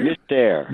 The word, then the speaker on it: Mystère: Mystère.